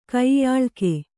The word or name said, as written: ಕೈಯಾಳ್ಕೆ